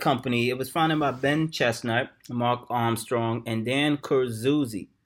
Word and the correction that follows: Kurzius: Am I pronouncing that right? I might be pronouncing it. – Kurzius. – Okay, Kurzius.